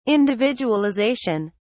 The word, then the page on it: individualization: British English